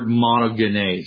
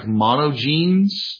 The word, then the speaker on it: monogenes: Finally, let's talk about the Greek word monogenes. And that's how that's pronounced. I know it looks like monogenes, you know, like somebody only has one set of genes in their DNA structure, but this is sort of a transliteration into English of a Greek word monogenes.